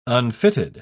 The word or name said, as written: unfitted